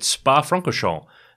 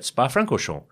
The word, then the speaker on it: Spa Francorchamps: Both Chazzy and Anton have been in Belgium this week at Spa Francorchamps uh, that is how you say it, Spa Francorchamps.